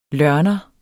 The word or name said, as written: lørner